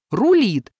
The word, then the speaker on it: рулит: Нормативное ударение должно быть на втором слоге «рулит». Но в этом меме все говорят «рулит», и это уже не ошибка, а скорее традиция или даже прием.